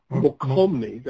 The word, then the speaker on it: Mokelumne: You've highlighted to me Feather River, American River, what is it, Mokelumne? – Mokelumne, that's how it's spelled, yeah.